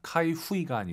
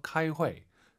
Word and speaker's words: 开会: 회의하다, 카이후이가 아니라 UI 사이에 에 넣어서 카이회, 카이회 요 발음도 잘하셔야 됩니다. 카이후이 안 됩니다.